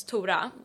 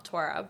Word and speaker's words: Tora: Actually, it's quite funny, because my name in Swedish, so it's Tora, like, not Tora, but Tora rhymes with... whore in Swedish, so it's Tora Hora.